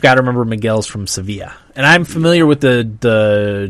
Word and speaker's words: Sevilla: I think it's pronounced Sevilla. – Sevilla, yeah. – At least that's how the soccer team pronounces it.